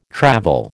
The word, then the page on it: travel: American English